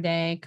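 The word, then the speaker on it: Dey: You can find me on Facebook, Summer Day.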